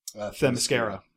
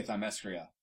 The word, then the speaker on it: Themyscira: How do you pronounce the plant, where it's from? – Themyscira. – Themyscira. Okay. Yeah. I used to say Themyscira.